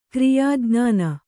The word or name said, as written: ಕ್ರಿಯಾಜ್ಞಾನ